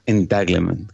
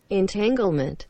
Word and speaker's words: entanglement: Estás hablando de entanglement, como dice, como se pronuncia. Sí, sí, un entrelazamiento. Entanglement. – a ver, a ver. – Entanglement.